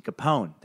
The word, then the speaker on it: Capone: See, Capone, that's how you do a name. Capone. Strong consonant sound at the end. It's got some meat on it.